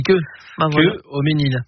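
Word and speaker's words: Quœux-Haut-Maînil: Ouais, ouais, mais ça s'écrit Q-U-X. – Ouais, mais ça se dit Cueil-Aumesnil.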